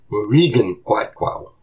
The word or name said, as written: Wurikun qátqáw